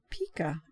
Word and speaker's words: pika: The word pika is from the Tungus language where it's pronounced pika, as in Pikachu.